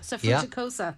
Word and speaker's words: suffruticosa: Now, apologies if I pronounce this incorrectly. Can Peonia Suffruticosa. – Suffruticosa. – Thank you.